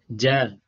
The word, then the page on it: j: Sanskrit